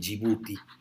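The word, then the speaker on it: Yibuti: Djibouti, se escribe Y, I, B, U grande, U, T, I,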